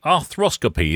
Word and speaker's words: arthroscopy: Now, I noticed that you do arthroscopy. Is that right? – I do, yes. – First of all, have I pronounced that correctly? – Arthroscopy. Yes, that's it.